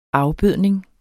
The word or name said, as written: afbødning